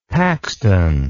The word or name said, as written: paxton